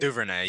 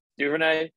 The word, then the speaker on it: Duvernay: Duvernay, yeah. – Duvernay.